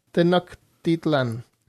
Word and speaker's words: Tenochtitlan: Si sé, algo es que es Tenochtitlan.